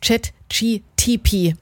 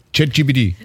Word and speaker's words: ChatGPT: Ja, das ist die ungenaue Aussprache vom KI-Chatbot ChatGTP. – ChatGPT.